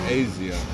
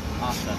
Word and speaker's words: Asa: Is it Asa or Asia? – Asa. – Asa. – Some people pronounce it Asa.